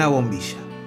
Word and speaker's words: bombilla: You'll hear that Rodrigo pronounces his double L's and Y's as sh, like in the word bombilla. – Y una bombilla.